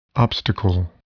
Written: obstacle

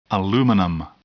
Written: aluminum

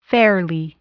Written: fairly

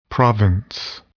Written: province

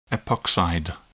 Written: epoxide

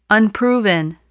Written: unproven

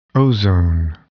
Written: ozone

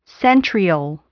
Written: centriole

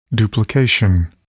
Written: duplication

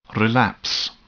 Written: relapse

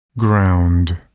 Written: ground

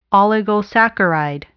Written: oligosaccharide

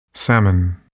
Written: salmon

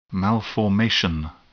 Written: malformation

